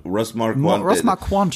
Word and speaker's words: Ross Marquand: Eine Sache, die dich interessieren könnte, ist ja Ross Marquand, heißt er doch. Das ist Ross Marquand. – Ross Marquand spricht man es aus.